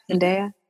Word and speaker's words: Zendaya: I think that's why, I mean, besides, Zendaya, did I say her name right? – Yeah. – Zendaya.